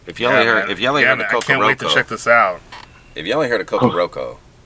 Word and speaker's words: Kokoroko: Coco Roco. K-O-K-O-R-O-K-O. Coco Roco. – It's only three letters in this whole name. So, yeah. And it's K's, right. K's, O's, and R's. – It's spelled like it, how it sounds, exactly. Honestly. – There's no C's.